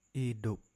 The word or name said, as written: Idup